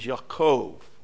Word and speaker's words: Yaakov: So there's no J to pronounce. So when you see a J, it's usually a Y. It's like Jacob. Jacob is Yaakov. Okay? Always a Y.